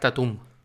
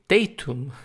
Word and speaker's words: Tatum: si tiene algún disco de Art Tatum, acentuado así en la última sílaba. No le suena y no lo entiendo, cómo es posible, no le suena Art Tatum de Pianist. Y entonces él me dice, oh, you mean Tatum, acentuado en la primera sílaba.